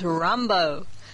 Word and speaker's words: Rimbaud: It's a French word, and it's spelled R-I-M-B-A-U-D, but it's pronounced Rambo. Like Rambo.